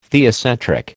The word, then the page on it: theocentric: American English